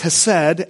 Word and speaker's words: hesed: This word means, in the Hebrew, is pronounced hesed, and what it means is this steadfast, continuing, covenantal love of God.